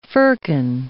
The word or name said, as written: firkin